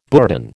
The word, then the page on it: bourdon: American English